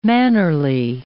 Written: mannerly

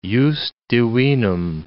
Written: jus divinum

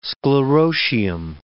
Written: sclerotium

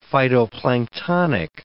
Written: phytoplanktonic

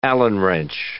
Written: Allen wrench

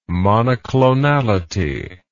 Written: monoclonality

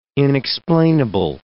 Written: inexplainable

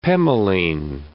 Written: pemoline

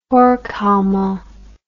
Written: Horkheimer

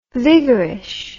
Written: viggerish

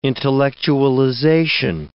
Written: intellectualization

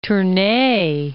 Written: Tournay

